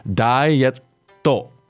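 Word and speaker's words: ダイエット: So just to go over pronunciation, we have dietto. – Dietto. – So a small tsu between the e and the to.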